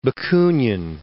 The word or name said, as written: Bakunin